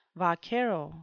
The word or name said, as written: vaquero